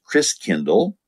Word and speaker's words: Christkindl: And the German pronunciation of Christ child is Christkindl, like kindergarten, kindercare. Kind means child and Christ means Christ. And so it was called, he said the gifts come from the Christkindl. Over the years, that got pronounced Kris Kringle. So Kris Kringle is really Kris Kindle, which means Christ child.